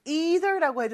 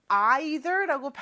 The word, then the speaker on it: either: 자, 근데 이 단어는 either라고 해도 되고 either라고 발음을 해도 상관없습니다.